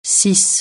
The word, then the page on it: situer: francais